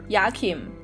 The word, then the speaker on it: Joachim: 对，他是德语，就是 Yakim。 – Yakim